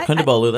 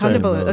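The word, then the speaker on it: Kondabolu: Did I pronounce that correctly? – Yeah, Kundabolu. That's Kundabolu.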